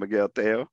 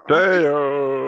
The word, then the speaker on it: Tello: His name's pronounced, if I'm saying it right, you know me, I'm not too bilingual. Teo, instead of Teo, it's spelled Tello, but pronounced Miguel Teo. So Teo.